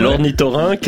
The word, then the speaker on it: ornithorynque: Bon, alors, je vais déjà vous dire comment ça se prononce. C'est ouais. L'ornithorynque. Alors, comment ça s'écrit, moi je le sais déjà, Anne, Cécile. – O-R-N-I-Y quelque part.